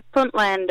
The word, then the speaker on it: Puntland: Yeah, Puntland.